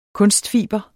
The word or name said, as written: kunstfiber